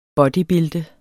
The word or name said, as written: bodybuilder